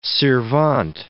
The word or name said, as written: sirvente